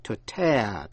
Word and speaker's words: tear: Nhưng nhớ là động từ cũng viết là T-E-A-R, đọc là to tear, tức là xé ra.